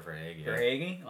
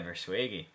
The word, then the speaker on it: Verhaeghe: Verhaeghe. Am I saying it correctly? – Carter Verhaeghe. – On the Florida Panthers. What a series. – Yeah. – Carter Verhaeghe. – Yeah, Verhaeghe. Listen, Florida Panthers, I got them winning the cup in my bracket.